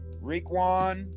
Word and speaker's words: Raekwon: No, I know you're worried about Raekwon, but that's definitely right.